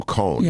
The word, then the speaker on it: Cohn: Cohen does? – No, Ferris has his rebuttal to Cohn.